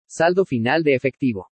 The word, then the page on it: SALDO FINAL DE EFECTIVO: spanish